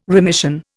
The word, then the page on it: remission: Indian